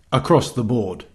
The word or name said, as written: across the board